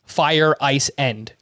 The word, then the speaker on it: Feireisen: I noticed that too, because when I phonetically spelled out his name, I listened to how they said it on the broadcast, and it literally sounded like fire, ice, end.